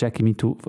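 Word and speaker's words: Jackie Mittoo: Ähm, ja, ich habe es vorher glaub, falsch gesagt. Jackie Mitu, mu musikalischer Direktor im, im Studio One.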